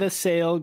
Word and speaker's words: Avisail: Avisail Garcia.